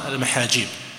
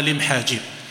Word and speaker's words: المحاجيب: في الزمن الأول نحو سنة 210 للهجرة دخلتها قبيلة من العرب تسمى المحاجيب، هم ينطقونها لمحاجيب،